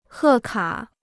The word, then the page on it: 贺卡: hè kǎ